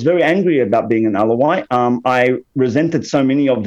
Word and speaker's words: Alawite: And when you speak of tribe, for people that haven't read the book, you're specifically speaking of Alawite.